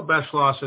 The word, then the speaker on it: Beschloss: And I think episode two is Michael Beschloss. Is that say that right? – Yes. Michael Beschloss is one of the nation's best known historians about the presidency, certainly.